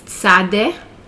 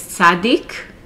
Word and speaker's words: Tzadde: Many people refer to it as Tzadik. So Tzadde or Tzadik is the 18th letter of the Hebrew alphabet. It's pronounced like TZ, as in Ritz or Blitz in English.